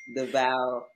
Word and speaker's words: Delvalle: And he was like, no, ma'am, that's not how you say my last name. – I might tell you that you accidentally didn't get it right, ma'am. It's Delvalle.